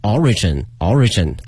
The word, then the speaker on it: origin: 那么这个词啊，表示一个起源，一个源头，难度呢不是很难，但是呢我们很多同学会把它的一个发音念错了，会把这个重音放到第二个这个 R 上啊，所以说一定要注意了，它的这个发音的重音在 O 上。 Origin can be 追溯啊，又学到这个追溯这个词， trace back， T-R-A-C-E 这个词哈，加一个 back，被动语态 be traced back to the southern and northern dynasties 啊，南北朝的时期啊。